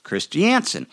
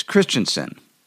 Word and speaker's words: Christiansen: Her uncle said, it's Christiansen, obviously accenting the first syllable.